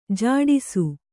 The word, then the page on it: ಝಡಿಸು: jhaḍisu